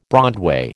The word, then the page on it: broadway: American English